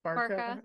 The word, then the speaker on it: Barca: Barca. That's Barca. What I say. – Like a parka.